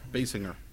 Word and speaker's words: Basinger: Basinger? How do you say her name? Basinger? – Basinger.